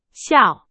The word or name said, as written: 笑